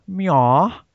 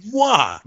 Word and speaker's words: moi: So the next one is what Arnold movie previously reviewed by Meow. I think is how you say that. Sorry. – It's moi.